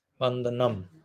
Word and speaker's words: Vandanam: I will pronounce but B. And the rest is like, it sounds bandana. A-N-D-A-N-A-M, Vandanam.